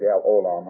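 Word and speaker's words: El Olam: O-L-A-M, hold on. I think that all those words have a long A, R. Used to be Al-Olam, I guess, be all right, but I think it's Al-Olam, how they pronounce it.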